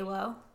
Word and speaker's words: Alo: A-L-O. – Yeah. – It's A-L-O, right? – Yeah. – Is it aloe? Aloe? – I say aloe. – Aloe? – I say aloe. – Ooh. – But aloe doesn't sound right because there's no aloe.